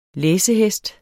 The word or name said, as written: læsehest